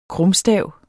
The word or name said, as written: krumstav